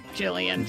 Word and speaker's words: Gillian: Did I say that right? Is it Gillian? – I think it's Gillian Anderson. – It is Gillian. – Gillian Anderson. – I'm always a little off.